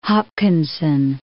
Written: Hopkinson

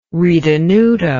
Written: ritenuto

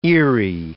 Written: eery

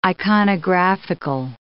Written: iconographical

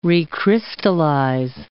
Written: recrystallize

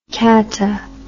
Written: cata

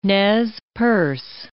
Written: Nez Perce

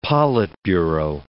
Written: politburo

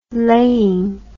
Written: laying

